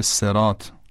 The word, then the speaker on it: صراط: احیانا اگر دوستانی تلفظ صاد در کلمه صراط